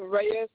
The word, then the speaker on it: Reyes: Darwin Reyes Rodriguez. – Okay, and can you spell that? – D-A-R-W-I-N. And then Reyes is R-E-Y-E-S.